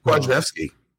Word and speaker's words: Kwasniewski: You just blew his pronunciation. You said you were going to get it right. – It's Kwasniewski. I'm giving you a hard time.